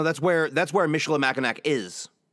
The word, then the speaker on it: Michilimackinac: Yeah, seven a maiden voyage from Niagara to how do we pronounce this word again? Michilimackinac. Niagara to Michilimackinac. It's a very long native word, indigenous Michilimackinac.